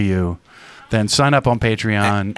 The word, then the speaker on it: Patreon: And then also, we would invite your participation to support the podcast by being one of our Patreons. Is that the correct pronunciation? – Yes, a patron on Patreon.